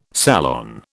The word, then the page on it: salon: British English